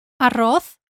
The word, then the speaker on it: arroz: Let's go through the five words arroz, rice, arroz, here we've got the double R sound.